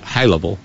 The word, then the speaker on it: Haleville: There are two members of the Alabama delegation to the House of Representatives, Robert Aderholt of Haleville, that's how you say it, Haleville, and Gary Palma of Hoover.